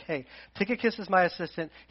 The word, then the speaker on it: Tychicus: To bring you up to date, Tychicus, which is how you pronounce it.